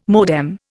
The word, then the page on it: modem: Indian